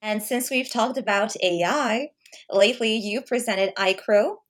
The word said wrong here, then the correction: iCOR: Am I pronouncing it correctly? – It's actually iCOR. – iCOR.